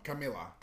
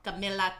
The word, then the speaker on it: Camila: Camila Cabello. – Oh. – Did I say it right? – No. Camila. – Camila Cabello.